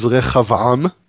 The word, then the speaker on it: Rehoboam: How do you pronounce Rehoboam in the Hebrew? – So Rehoboam is Rehavam, which means expansion of the nation.